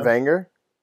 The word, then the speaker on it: Wenger: Wenger? – Sure. – It's pronounced with a V.